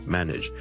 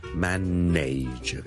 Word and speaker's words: manage: 今日想同大家讲 manage 呢个字嘅发音，manage M A N A G E manage。由于呢个字后面 N A G E 咧，nage 咧系串做 N A。好多人就会将它读成 manage 嘅，但事实上咧，我呢个字咧系读 manage nudge nudge nudge，你当系好似 nidge 咁读。